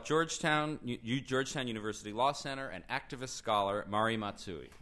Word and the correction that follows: Matsuda: Matsuda. Apologize for the mispronunciation.